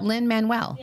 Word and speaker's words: Lin-Manuel: No, I think it's Lin-Manuel, isn't it? Is it Lin-Manuel? I think he pronounces it Lin-Manuel.